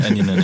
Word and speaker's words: Eminem: Eminem spricht man es richtig aus. – Eminem? Eminem, ja.